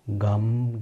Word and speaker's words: Gam: Or with the simpler gum pronunciation, Om Gam Ganapataye Namaha. Om Gam Ganapataye Namaha. Om Gam Ganapataye Namaha. Om Gam Ganapataye Namaha. You may choose the version of the pronunciation that you prefer for the bija.